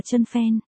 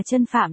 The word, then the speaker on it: Chân Phạm: Hôm nay M xem lại thì tiếng Trung của bạn này là chân phen, chân phản, thì đọc là chân phạm là đúng.